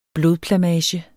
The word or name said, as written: blodplamage